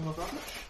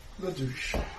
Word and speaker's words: Lavache: You have Mrs. Whittingham and Gaston Lavache? – Yeah. La douche. – It's pronounced La Vache with a V.